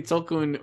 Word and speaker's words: Tolkien: It should really be Avatar for the Tolkiens. – That'd be great. Give the whales a SAG award. – Yeah. Mm-hmm. I hope they got their nomination certificate. They better. – I want to see Tolkien, or how do you say it? – Tolkien.